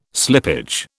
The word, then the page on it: slippage: British English